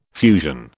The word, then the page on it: fusion: British English